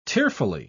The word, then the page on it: tearfully: British English